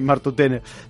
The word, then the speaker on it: Martutene: Sí, Martutene. Veus, això ja em sorprèn menys, però anava a dir, no sé si em sorprèn més que t'enxironessin a Sant Sebastià o que sàpigues pronunciar Martutene correctament, eh?